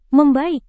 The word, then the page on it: membaik: Indonesian